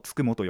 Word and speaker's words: 九十九: で、つくも神って書くものがあれば、えっ、ー、と、もう一つの書き方で、 99 って漢字で書く。 9 と 10 と 9、あの数字の。って書いても、つくもと読むと。